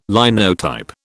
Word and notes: Linotype: British English

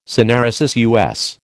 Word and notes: syneresis: American English

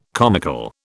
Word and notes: comical: British English

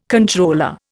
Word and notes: controller: Indian

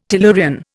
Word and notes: tellurian: Indian

